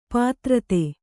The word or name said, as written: ಪಥಾರ್ತ